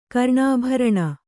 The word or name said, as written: ಕರ್ಣಾಭರಣ